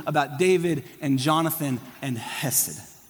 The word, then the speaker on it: Chesed: And so kids, you don't just say Hesed, you say Chesed. Can you say Chesed? Chesed. If you're not coughing something up from deep down inside of you, you're not saying it right.